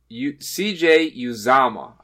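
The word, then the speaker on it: Uzomah: So he's out for the year, put on the IR, and today the team came out and said, CJ Uzama is actually how you say it. CJ Uzama. I looked it up.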